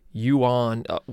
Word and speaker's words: yuan: It's kind of pronounced the same, but it's yuan, yuan is the Chinese, and yen, yen is the Japanese.